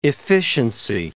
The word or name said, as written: efficiency